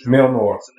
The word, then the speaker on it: Mjolnir: Mjolnir. Mjolnir. – Mjolnir. – Mjolnir. – Meow meow. – I'll never get this. It's spelled M-J-O-L-N-I-R. – Yeah, just pronounce it as if the J was a Y.